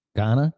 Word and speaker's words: Ghana: Brazil, Mexico, Puerto Rico, Sweden, Guana. Is that how you say it? Ghana, Guana, G-H-A-N-A. – Ghana.